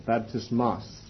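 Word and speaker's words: baptismos (βαπτισμός): The word that is found here is baptismos, or baptismos, the emphasis on the last syllable, baptismos, or in the plural, moi, baptismoi.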